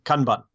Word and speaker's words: Kanban: The rest of the world, I think, taking their lead from British English, would say Kanban. And the proper Japanese pronunciation, I think, is Kanban. It's very staccato.